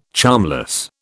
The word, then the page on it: charmless: British English